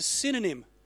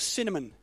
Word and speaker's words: synonym: Now that word knowledge of good and evil is a synonym, not a cinnamon. It's a synonym. I said it wrong.